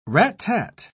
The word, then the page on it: rat-tat: British English